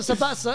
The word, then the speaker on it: Sabitzer: Bro, in Arabic, we pronounce it Sabatza, innit?